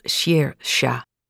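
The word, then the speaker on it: Saoirse: Dat spreek je trouwens uit als Sersha. Ik weet dat Sersha Ronan haar uiterste best heeft gedaan om de hele wereld te laten weten dat het Sersha is.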